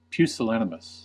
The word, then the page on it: pusillanimous: American English